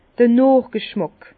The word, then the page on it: d'r Nochg'schmàck: Bas-Rhin; Herrlisheim